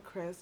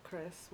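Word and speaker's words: Kris: Mine is Journeys with Chris, Journeys with Chris, with a K, K-R-I-S. – And I'm also at denise.osei. Last name is spelled O-S-E-I, not Osei, can you see?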